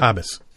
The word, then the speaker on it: Haabjas: The J is silent? – Habas. Yep. So, check that out.